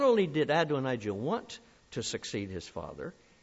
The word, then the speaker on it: Adonijah: And it's about one of his other sons that I want to build this sermon around today, a man named Adonijah. That is spelled A-D-O-N. That's the Adon, A-D-O-N, and then I-J-A-H, I-J-A-H. Adonijah.